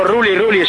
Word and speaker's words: Rulli: No, Rulli, se pronuncia con una L solo, sin Illarra y sin Markel.